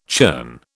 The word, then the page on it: churn: British English